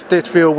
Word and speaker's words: Stinchfield: and we are very pleased to have Grant Stinchfield with us.